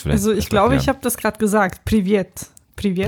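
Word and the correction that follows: Privet (Привет): Privet und Privet, genau.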